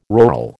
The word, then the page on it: rural: American English